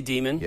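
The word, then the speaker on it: daemon: Yeah, I say Demon. – Yeah. – And then, of course, a lot of Unix people say Damon. And, you know. – It's probably worth saying Damon because it's spelled D-A-E.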